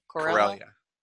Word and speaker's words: Corellia: So one of the first things that we see in Han and Kira trying to escape Corella, am I saying that correctly? – Corellia. Corellia.